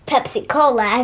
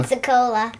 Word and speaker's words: Pensacola: They have to move to, Pepsi Cola, as her little sister says. – Pepsi Cola. – Pensacola. – Pensacola, Florida. – Yeah, Pepsi Cola. – Yes. – Finally said it right, and spoiler alert again, but...